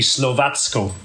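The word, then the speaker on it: Slovácko: I have to actually look and see how it's spelled, first of all, because if it has the symbol over the C, so no, it doesn't have the symbol over the C, so it would be Slovacko.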